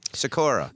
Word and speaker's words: Sikora: I mean, unless you pronounce it the Polish way, which is Sikora. – Okay. So then I'm not saying it right. – No, my God. I mean, I like to say Sikora because it's fun to say it like that, but my dad always gives me grief.